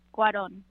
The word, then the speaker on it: Cuarón: Something interesting that I found when looking up this film is that people were really confused when Alfonso Cuarón decided... Is that how you pronounce his name? Cuarón? – Cuarón.